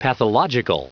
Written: pathological

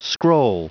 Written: scroll

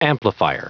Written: amplifier

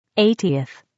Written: 80th

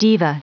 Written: diva

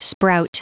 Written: sprout

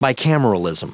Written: bicameralism

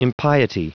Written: impiety

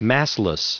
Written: massless

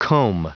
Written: comb

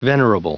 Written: venerable

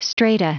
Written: strata

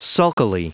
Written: sulkily

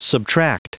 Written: subtract

